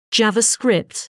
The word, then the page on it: JavaScript: British English; /ˈdʒɑːvəˌskrɪpt/